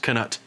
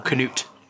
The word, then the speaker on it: Canute: No. It's specifically pronounced Canute, or Canute. If you prefer Canute the Great.